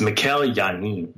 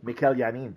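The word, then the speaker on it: Mikel Janín: Is it Mikel or Michael? – It's Mikel Janin. – Mikel Janin, thank you very much. – Yes.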